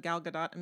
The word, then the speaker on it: Gal Gadot: I'm like, I guess I know how to say her name now, because everyone said Gal Gadot a million times. – Yeah, I would have thought for sure the T was silent.